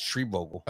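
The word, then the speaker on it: Schreibvogel: The limited series will center around Carol Baskins, big cat enthusiast, who learns that fellow exotic animal lover Joe Exotic, can you pronounce his last name? – Shreebogle. – Yeah, no, that's not it either. – No, it is. It's Shreebogle.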